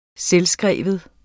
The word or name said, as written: selvskrevet